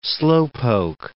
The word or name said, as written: slowpoke